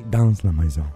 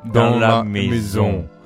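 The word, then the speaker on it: dans la maison: La S non si pronuncia. – Scusami, che ne so che è francese. – No, allora, un po' più nasale. – Don la maison. – No, di più.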